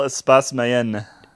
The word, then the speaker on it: Espace Mayenne: To Lavel Espace Mayenne. – You put some Spanish on that. – Sorry. Espace. – Laval Espace Mayenne. – Oh, Espace?